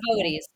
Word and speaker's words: Boötes: We mentioned the Boötes. Am I saying that right? – Yeah. – Okay. So Boötes.